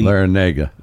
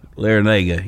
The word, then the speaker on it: Larrañaga: the road you know, Larinatus, is that how you say it? I think so. Man, that guy's a good coach that goes totally Larrañaga. Larrañaga, yeah.